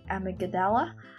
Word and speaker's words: amygdala: Um, the amygdala, yeah, I said that right.